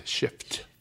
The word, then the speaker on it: shift: It has an F in it, shift. Yes. I always tell my listeners that they find that funny because it sounds like I'm saying, yeah, yeah, you have to pronounce the F strong. Shift.